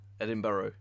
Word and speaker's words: Edinburgh: Edinburgh, yeah.